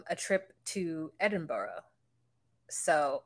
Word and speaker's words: Edinburgh: It's bra at the end. Like B-R-A. Edinburgh.